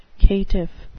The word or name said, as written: caitiff